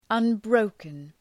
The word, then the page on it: unbroken: ʌn’brəʋkən